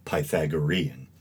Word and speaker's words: Pythagorean: And the person I was talking to, you know, kind of as nicely as possible, let me know that the proper pronunciation was Pythagorean.